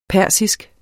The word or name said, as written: persisk